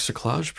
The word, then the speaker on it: cerclage: And then I've also heard about a robotic cerclage program.